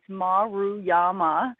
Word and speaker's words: Maruyama: It's I like to tell people to say it two syllables at a time. It's Maruyama.